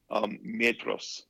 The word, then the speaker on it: Maedhros: Wait, spell it again. – M-A-E-D-H-R-O-S. – Oh yeah, Metros. – Yes, that's how you say it right there, friends.